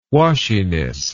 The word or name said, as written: washiness